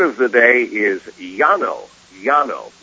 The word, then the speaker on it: llano: Now, you can pronounce it a little differently if, for example, you live in what's called the port area, Buenos Aires or Montevideo, but typically the word of the day, L-L-A-N-O, that's how you spell it, L-L-A-N-O, typically you would say Llano.